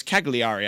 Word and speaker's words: Cagliari: They were in action against it's Cagliari. I butchered the pronunciation earlier.